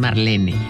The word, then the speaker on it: Marlene: que todo el mundo dice Marlene, pero es Marlene.